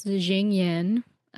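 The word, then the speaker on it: Xingyin: I've been pronouncing this Jingyin. – That's right.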